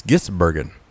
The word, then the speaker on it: Gisbergen: We can be calling him Gins. It's Gisbergen.